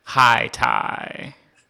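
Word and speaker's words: Hayti: there's another town spelled Haiti, pronounced High Tie.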